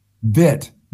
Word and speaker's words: Witt: Okay, so uh, Kat- Katarina Witt. Vit. Vit. – Katar- w- Kat- pronounced like a V.